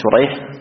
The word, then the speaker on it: شريح: هنا أسماء الآباء اتفقت، لكن أسماء الرواة اتفقت خطا واختلفت لفظا، فسريج وشريح نفس الحروف باختلاف النقاط، هذه تنطق شريح وهذه تنطق سريج، هذه بالشين المعجمة وهذه بالسين المهملة، وهذه بالحاء المهملة الأولى شريح، والثانية بالجيم المعجمة أي التي عليها نقطة.